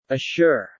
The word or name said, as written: assure